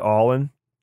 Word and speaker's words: Ollin: It's spelled O-L-L-I-N, but they pronounce it All-In.